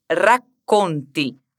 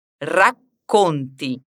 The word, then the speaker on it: racconti: Preste atenção na palavra racconti. Aqui temos um R no começo de palavra e um duplo C. Lembra daquela pausa que a gente dá antes de completar a pronúncia disso? Racconti.